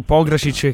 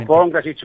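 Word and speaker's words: Pongračić: Prima tu hai giustamente posto l'accento su Pongracic, che qui a Firenze sta convincendo. – Io dico Pongracic per dire, certo.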